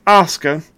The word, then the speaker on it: Asuka: I mispronounced it Asuka, just reading it how it looks. And I'm like, oh, wait a minute, that's not right. It's Asuka versus Rhea Ripley.